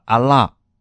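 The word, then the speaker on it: 阿 拉 (ἀλλά): 接下来雅各书第二章十八节，十八节说，必有人说，这个必这个字在希腊文当中读作阿拉，它的英文翻译是 but，但是，所以它是一个相反的。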